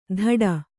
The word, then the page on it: ಧಡ: kannada